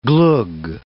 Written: glogg

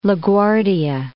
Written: La Guardia

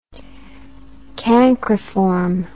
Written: cancriform

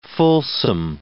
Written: fulsome

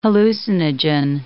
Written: hallucinogen

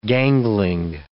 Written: gangling

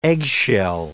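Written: egg whisk

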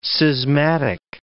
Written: schismatic

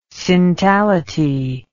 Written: syntality

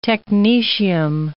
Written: technetium

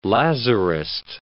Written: Lazarist

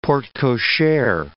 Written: porte cochere

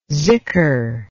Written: zikr

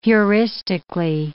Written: heuristically

